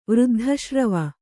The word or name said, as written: ವೃದ್ಧಶ್ರವ